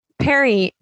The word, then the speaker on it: Perrine: So this is the town, Perrine. And I actually wrote PER/INE like 9 because I didn't want to mispronounce it. – I appreciate you so much. – Thank you. Perrine was where they had a couple of structures that were up.